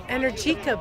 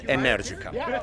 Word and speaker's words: Energica: Let me say it with Italian pronunciation. Welcome, everybody. The right name is Energica. – One more time. – Energica. Probably speaking more American-friendly, it sounds more Energica, but yeah, the right pronunciation is, once again, Energica.